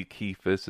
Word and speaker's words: Kephas: and that he was seen by Kephas," yes, hard C, Kephas, it's a transliteration of the Aramaic Kepha, which means a stone.